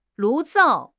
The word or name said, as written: 炉灶